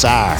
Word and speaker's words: Cyr: C-Y-R is how it's spelled. It kind of sounds like you're saying something different, and we're still not super duper sure what that means.